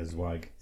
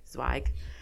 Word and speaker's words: Zweig: This is from the Wall Street Journal, written by Jason Zweig. Is that how you say his name? – Yeah, Zweig. – Zweig.